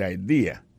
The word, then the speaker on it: idea: C'est I-D-E-A, ça veut dire idée. That's a good idea. Alors, accent vient de tomber dans la deuxième syllabe là, one idea. Alors, moi c'est idée yo fait le trois syllabes vraiment I D A on dit grain mot comme ça trois syllabes là